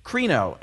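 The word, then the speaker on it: krino (κρίνω): Well, the word that's translated judge here is a Greek word that's pronounced krino, and it means to separate, to appraise, to make a distinction.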